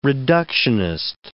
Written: reductionist

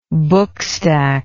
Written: bookstack

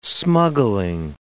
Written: smuggling

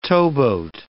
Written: towboat

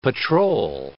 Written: patrol

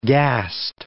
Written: gassed

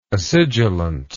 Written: acidulant

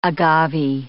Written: agave

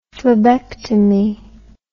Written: phlebectomy

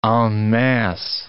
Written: en masse

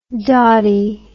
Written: dawtie

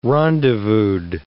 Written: rendezvoused

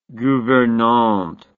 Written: gouvernante

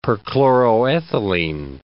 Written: perchloroethylene

